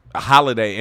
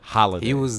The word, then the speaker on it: Holladay: Like, Holiday, and it's spelled Holladay. Holiday.